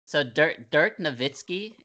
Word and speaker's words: Nowitzki: it's like Dirk Nowitzki. It's actually even though it's a W, it's pronounced V with a V sound, yes.